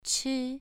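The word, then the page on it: chi: tone 2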